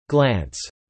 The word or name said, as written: glance